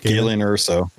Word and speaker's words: Galen Erso: Galen Erso.